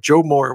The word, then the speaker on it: Joe Moore: And watch out, they're going to win the Joe, I'm going to say it right, Joe Moorhead Award. Joe Moore Award. – No, Joe Moore. Just, not Joe Moore. Joe Moorhead is the failed Mississippi State head coach.